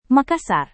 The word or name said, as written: makassar